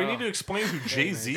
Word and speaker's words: Jay-Z: With the artist, I believe I'm pronouncing this right, Jay-Z? – Jay-Z, yes.